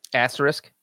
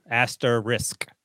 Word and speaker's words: asterisk: Asterisk. How do you say it? – Risk at the end. Asterisk. – Oh, not asterisk.